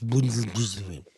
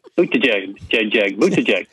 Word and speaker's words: Buttigieg: You pronounce his name wrong. It's Buttigieg. – Buttigieg.